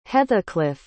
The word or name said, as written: hetheclif